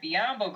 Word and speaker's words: Biyombo: I didn't have Whiteside going to the Lakers because he said he wanted a stable organization, but I do have Bismack Biyombo going to the Lakers. Ooh. I know it's not a huge signing, but Biyombo rhymes with Mutombo, and the Lakers can get themselves a guy who can protect the rim because Hibbert is probably out of there.